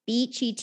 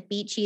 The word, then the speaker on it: Beechie: It's Miss Beechy Teaches, and Beechy is spelled like B-E-E-C-H-I-E, because that's my teacher name.